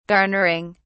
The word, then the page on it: garnering: British English